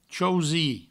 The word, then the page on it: chausir: chausi; provençal